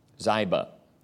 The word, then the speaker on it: Ziba: "And there was of the house of Saul a servant whose name was Ziba." You'll notice that that's a long I, Ziba.